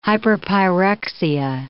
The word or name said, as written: hyperpyrexia